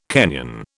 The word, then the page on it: Kenyan: British English